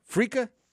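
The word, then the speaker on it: Freekeh: Did I say it right? Frika? – You sure did.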